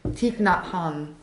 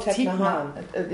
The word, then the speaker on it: Thich Nhat Hanh: Thich Nhat Hanh. – Oh, Thich Nhat Hanh.